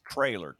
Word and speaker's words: Traylor: Jeff Trainer? Trailer or Trainer? – Trainer, yeah. – Let me look him up before I mispronounce his. It's Trailer, T-R-A-Y-L-O-R, Jeff Trailer.